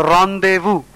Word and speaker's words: rendezvous: A synonym is a rendezvous. R-E-N-D-E-Z-V-O-U-S, which means a secret meeting between lovers. Rendezvous is the pronunciation. Tumid, T-U-M-I-D.